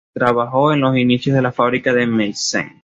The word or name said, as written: inicios